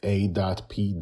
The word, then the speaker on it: APOC: Our guest today is Hannah Spikings, creator of the webcomic APOC, which is spelled A.P.O.C.